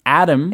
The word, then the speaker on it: atom: But usually we say it just like the name. – Yeah. – The boy's or man's name, uh? – Adam. – Yeah. Adam.